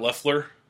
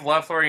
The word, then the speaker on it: Loeffler: Next on the list was Scott Loeffler. That's how you say it, right? – Yep. – Loeffler.